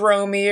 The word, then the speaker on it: Romy: My name is Romy. It rhymes with homie.